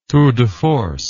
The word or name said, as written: tour de force